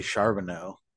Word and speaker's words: Charbonnet: I mean, I think Charbonnet, right? That's how you pronounce it. – I mean, if you're fancy, I say Charbonneau.